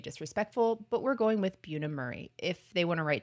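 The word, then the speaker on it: Bunim Murray: Am I saying that right? Is it Buna Murray? – That's how I would say okay.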